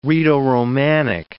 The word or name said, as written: Rhaeto-Romanic